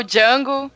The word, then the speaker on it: Django: Aqui é o Django e o D é mudo.